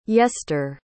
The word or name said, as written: yester